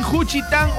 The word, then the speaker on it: Juchitán: Ahí en Juchitán, Oaxaca.